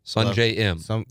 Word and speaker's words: Sungjae Im: Sungjae Im. – Sungjae Im. – Yeah, that's how you say it.